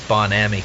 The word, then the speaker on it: Bon Ami: I believe it's Bon Ami, but in this country, nobody calls it that. It's Bon Ami cleanser.